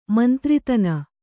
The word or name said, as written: ಮಂತ್ರಿತನ